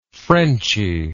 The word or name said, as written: frenchy